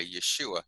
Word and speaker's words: Yeshua: So the word Yeshua, which how it's pronounced in Hebrew, you can almost hear how it's similar to Yahweh, Yeshua, and it basically means God saves or Yahweh saves.